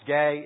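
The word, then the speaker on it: gay (γῆ): It's pronounced gay, and it means the whole thing or any part of it.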